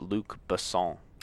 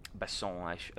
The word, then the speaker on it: Besson: I believe it's pronounced Luc Besson. – Besson, yes.